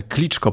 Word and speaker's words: Kliczko: przecież wymawiamy kliczko, prawda, to jest e, wymowa, transkrypcja z języka rosyjskiego. Gdybyśmy posługiwali się ukraińską wersją, to mówilibyśmy kliczko.